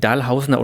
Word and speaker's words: Dahlhausen: Deine Geschichte spielt in Dahlhausen. Ne? Das nennen die Dahlhausener und Bochumer übrigens Dahlhausen, also auf der ersten Silbe betont.